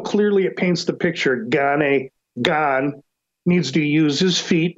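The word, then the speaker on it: Gane: Jones' path to stubbing his toe and Gani's path to earning a victory, it's Gan, and I'll mispronounce it 20 times.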